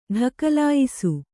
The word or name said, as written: ಢಕಲಾಯಿಸು